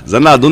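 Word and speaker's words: Xanadu: Zanadu, na verdade, a gente abrasileirou o, o Zanadu né, em inglês, que é com X, né, mas a pronúncia é, é esse som de Z, então a gente trouxe pro, pro português, né? – Ah, aquele que passava na sessão da tarde.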